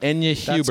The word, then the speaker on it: Enya Hubers: Am I saying that right? Enya Hubers. – That's correct. Enya Hubers, and she's a previous Spelling Bee participant.